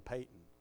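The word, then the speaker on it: Paton: You know, I was reading y'all those excerpts out of that missionary book, and then finally somebody told me I was pronouncing the guy's name wrong. I was always calling him Patton, and then somebody said, no, it's really Peyton.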